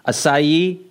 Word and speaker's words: acai: Acai? Yeah, that's what it is. – Oh, the acai berry thing? Yeah. – I should call it Kai. – Acai berry.